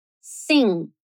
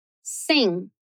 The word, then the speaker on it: sing: Sing. Sing. Então, se você perceber que a gente não chega a pronunciar o G, mas o próprio N vai ficar diferente.